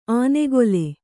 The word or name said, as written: ಆನೆಗೊಲೆ